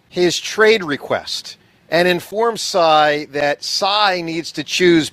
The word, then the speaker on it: Tsai: How do I say this name? Joe what? – Tsai. – Joe Tsai.